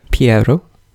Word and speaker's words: Pierrot: So the two images that come out of their minds at the beginning, Agatha has a Pierrot. I'm waiting to see if that is the correct pronunciation. – Piero.